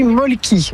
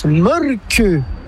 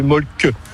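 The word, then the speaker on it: Mölkky: Ça s'écrit Molki, mais ça se prononce Molke. – Le Molki. – Plutôt un E qu'un E, mais pas un U. Comme ça fait Molke.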